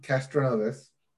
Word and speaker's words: Castroneves: Sports Corner and sports happened all over the place this week, starting with the Indy 500, Helio Castroneves. Did I pronounce that wrong? – Castroneves.